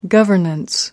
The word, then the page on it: governance: American English